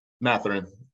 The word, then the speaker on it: Mathurin: And then they don't really see any of the top-tier rookies until December, when they're going to see Canada's own Benedict Mathurin of Indiana. What do you think of Mathurin? Am I saying that right? – Mathurin.